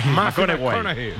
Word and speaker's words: McConaughey: Matthew McConaughey. – Sí, sí, sí, sí. – Hay que ir corrigiendo la pronunciación. – McConaughey. Matthew McConaughey.